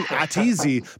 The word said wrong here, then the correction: A to Z: but now that I think about it, is it A to Z? Tell me about A to Z, your company, and your overall goal and your mission. – It is A to Z, yes.